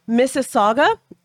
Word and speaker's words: Mississauga: I meant the issues around Mississauga and Toronto.